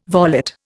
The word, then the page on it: volet: Indian